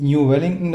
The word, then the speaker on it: New Wellington: Äh, New Wellington, das, das wird aber ausgesprochen, also die meisten sagen New Wellington. Also das New ist nicht so, weißt du, wie New York.